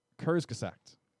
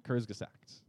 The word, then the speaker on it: Kurzgesagt: Yeah, and there's a YouTube video channel, right, called Kurzgesagt. – Come again? – Bless you. – Kurzgesagt. – Okay. – Kurzgesagt. I'm saying it with the wrong emphasis. – You're putting the emphasis on the wrong syllable.